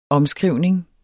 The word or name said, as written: omskrivning